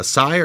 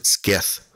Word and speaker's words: scythe: No, we have to discuss that you pronounced it skith.